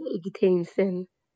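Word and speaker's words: Eğitim Sen: اتحادیه ایگی تیمسن. – آها بله بله درست.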